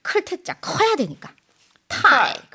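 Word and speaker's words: 타이 (太): 사랑 애자는 발음을 I 하고 발음합니다. – I. – 그럼 클태자는? – 타이.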